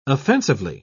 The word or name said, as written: offensively